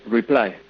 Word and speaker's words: Reply: no sé cómo se dice el nombre de esta empresa, si se mantiene la pronunciación estadounidense, si utilizamos pronunciación española. Buenas tardes. ¿Cómo se llama la empresa? – Hola, buenas tardes. Reply, sí.